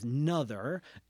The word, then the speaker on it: Noether: I'm pretty sure the German pronunciation is something like Noether. You can tell I'm not a native German speaker. So I'm going to go with what it reads in English as Noether, Emmy Noether.